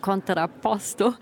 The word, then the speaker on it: Contrapposto: Ja, du hast ihn auch so wunderschön italienisch gerade ausgesprochen, Contrapposto.